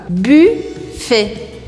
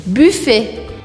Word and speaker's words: buffet: Buffet, buffet. Voilà. Não pronuncie esse T no final.